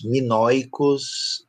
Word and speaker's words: minoicos: Esas letras son muy, muy parecidos a la escritura de los ah, ah, ¿Cómo se dice en portugués? mino, minaos, minaos? Minoico, Minoicos.